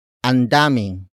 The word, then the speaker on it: andaming: Ang daming can also be expressed as andaming.